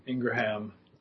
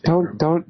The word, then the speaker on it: Ingraham: I-N-G. – Yeah, thank you. – Ingraham. I mean, that's how it looks like it's spelled, but it's Ingram.